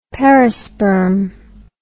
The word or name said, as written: perisperm